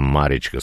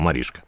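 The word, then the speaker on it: Mariska: СМС-сообщение 83 заканчивается как венгерка, она не Маришка, а Маричка, с уважением, Геннадий. Да, как венгерка, согласен, но мы произносим ее, как она написана. Поэтому, Маришка, спасибо за ваше внимание.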